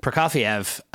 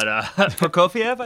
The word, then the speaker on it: Prokofiev: I'm not Russian, but Prokofiev, I think, is what I'm Prokofiev, I think, is correct, right?